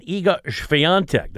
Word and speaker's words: Iga Świątek: and Iga Świątek.